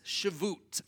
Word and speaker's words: Shavuot: The first one is Passover, and the second one is what I'm told is pronounced as Shavuot.